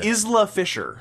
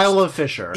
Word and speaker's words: Isla Fisher: Isla Fisher, I believe her name is pronounced. – Isla Fisher.